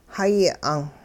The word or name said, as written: hayi 'ang'